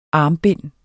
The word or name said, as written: armbind